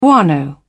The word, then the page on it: guano: British English